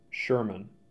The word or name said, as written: Sherman